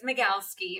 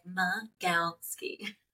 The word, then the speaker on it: Magalski: It's Magalski. – I know, I'm not even going to try. – Yeah, it's Magalski. And people are always like, what? So I'm like, Magalski.